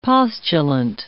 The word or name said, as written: postulant